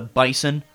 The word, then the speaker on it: Bison: Not the Bison, the Bison. – The Bison? – The Bison. – Okay. – That's how they say it. – I always thought it was Bison. – Sort of like Boise.